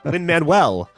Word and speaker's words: Lin-Manuel: Lin-Manuel. – Yes. – No. I was saying Lin-Manuel, apparently. – Oh, sorry. You were adding an extra syllable in there.